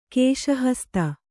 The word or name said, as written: ಕೇಶಹಸ್ತ